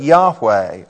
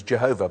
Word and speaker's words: Yahweh: you're actually reading the English translation of the Hebrew word Yahweh, or it was anglicized by a previous generation as Jehovah, but that's not quite the right way to pronounce it or the right spelling.